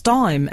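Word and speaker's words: Daim: He says, Dame bars are still pronounced dime, FYI.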